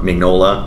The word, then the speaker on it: Mignola: Yeah, Mignola. – Mignola.